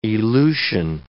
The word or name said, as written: elution